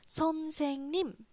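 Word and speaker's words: son sing nim (선생님): 所以连起来就是 son sing nim，大家可以跟我读一下啊，私底下跟我读一下 son sing nim。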